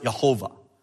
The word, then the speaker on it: Jehovah: Okay, there's only consonants. You can put vowels in there. I prefer to pronounce it Jehovah.